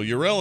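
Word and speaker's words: Yrel: We had a big argument on Core whether you're supposed to pronounce her name Yurel or not, but that's how Blizzard pronounces it, so F off, everybody. That's how they say it.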